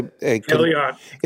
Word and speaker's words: Haleon: A second choice is, I don't know whether it's Haleon. Is that how you pronounce it? It's a Haleon.